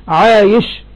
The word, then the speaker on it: عايش: مثاله: عايش بياء خاتمة الحروف أي التهجي، وشين معجمة، وعابس بباء موحدة، وسين مهملة، يبقى اسمه عايش وعابس.